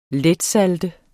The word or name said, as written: letsalte